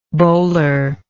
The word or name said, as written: bolar